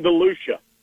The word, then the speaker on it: DeLucia: Is it DeLucia? – It's Dylan DeLucia.